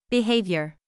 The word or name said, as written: behavior